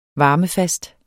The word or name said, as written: varmefast